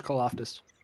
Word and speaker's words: Karlaftis: Now, we gave them George Karloff. – George, George Karlaftis.